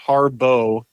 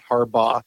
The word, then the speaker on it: Harbaugh: And by the way, it is pronounced Harbaugh, not Harbaugh. I've already been yelled at about that.